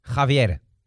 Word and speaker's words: Javier: Est-ce que, tu sais bien prononcer le Javier C'est important. – Javier. – Muy bien, perfecto.